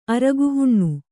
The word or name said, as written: ಅರಗುಹುಣ್ಣು